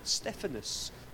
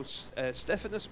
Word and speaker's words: Stephanas: called Stephanus. That's his Greek word. And it's spelled S-T-E-P-H-A-N-A-S. So it's like Stephen, but don't be confused with Stephen from the Bible.